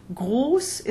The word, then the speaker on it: Groß: Groß is long, but Ross is short.